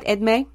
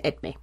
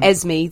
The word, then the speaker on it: Edmé: I think I'm saying that right, Edmé. It's E-D-M-E, French name Edmé. As long as I don't call him Esme, then it's fine.